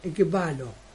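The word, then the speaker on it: ekbalo (ἐκβάλλω): Hay un verbo griego que se llama o se pronuncia por la palabra sacar, ekbalo.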